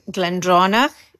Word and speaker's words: Glendronach: Benriach in Speyside, Glendronach in the Eastern Highlands, and Glenglassa on the coast.